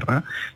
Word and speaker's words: Ra: Generalmente lo llamamos aquí en España Ra, pero habría que pronunciarlo más, como más parecido sería rey.